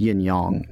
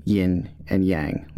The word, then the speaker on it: yin yang: Um, and then uh, yeah, and then yes, it's pronounced yin yang, not yin and yang. Like that's incorrect. That that was it.